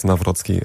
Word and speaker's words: Nawrocki: Äh, mein Nachname kommt aus dem Polnischen. Ähm, meine Eltern sind dort geboren. Ich habe noch eine polnische Staatsbürgerschaft. Und tatsächlich wird der Nawrocki geschrieben, aber äh, auch wenn er da kein TZ drin ist, wird Nawrocki ausgesprochen.